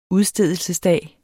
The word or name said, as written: udstedelsesdag